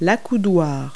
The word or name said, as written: armrest